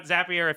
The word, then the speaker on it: Zapier: Check out Zapier if you have not. It rhymes with happier because that's how it will make you feel.